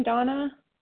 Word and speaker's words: Dana: Good morning, Donna. – Good morning. This is Donna B., recovered compulsive overeater and bulimic, calling from Denver, Colorado. I do spell my name D-A-N-A, although it's pronounced Donna.